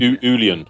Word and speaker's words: Uilleann: Uilleann.